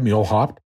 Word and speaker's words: Muehlhopp: My name is Ted Muehlhopp, pronounced like donkey jump.